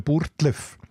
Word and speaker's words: Burgdorf: Das ist die etymologische Wurzel ist dann aber in der Aussprache, heute sagt man ja Burtlef, mhm.